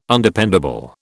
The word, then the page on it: Undependable: British English